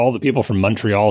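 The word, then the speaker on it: Montréal: The one I learned, of course, is Montreal with the first syllable sounding like the word hunt, but with an M in front of it.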